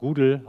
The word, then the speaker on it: GDAL: Viele werden hier wahrscheinlich GDAL oder auch Google ausgesprochen kennen.